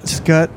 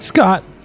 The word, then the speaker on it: Scut: Scut. – Not Scott. – Scut. – Gut. – Farkas. Patrick, what color does Ralphie use to describe Scut's eyes? – Oh, yellow eyes.